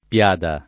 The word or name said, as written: piada